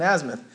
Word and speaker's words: azimuth: Okay, that's where the North Star is, and they'll take, I think it's called an azimuth. Is that the right word? But they use, it's probably not, I probably just butchered it. Huh? It is. Okay, it's an azimuth.